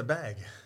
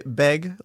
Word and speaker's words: bag: Wait, how do you say bag? – It's a bag. – Oh, you don't say it beg? Like, No.